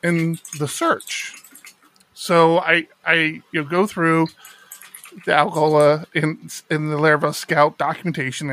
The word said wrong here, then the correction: Algolia: And when I log into the Algola, am I saying that right? Algola? – I wasn't going to correct you. Algolia. I was going to make them sponsor us to get it pronounced correctly.